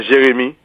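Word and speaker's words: Jérémie: And I heard an interview from Al Jazeera of a resident in the town of Jeremy. Am I pronouncing it correctly? – Yes, Jeremy.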